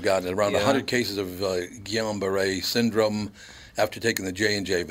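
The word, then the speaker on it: Guillain-Barré: Guillain Barre. – Yeah, it's in French. It'd be Guillain Barre.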